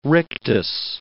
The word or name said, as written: rictus